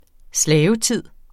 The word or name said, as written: slavetid